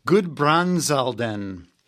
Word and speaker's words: Gudbrandsdalen: Gudbrandsdalen, and that's in Oppland County.